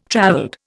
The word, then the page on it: travelled: Indian